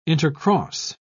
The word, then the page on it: intercross: British English